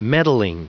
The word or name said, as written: meddling